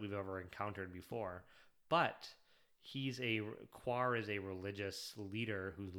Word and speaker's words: Quar: It's Q-U-A-R, so Quar.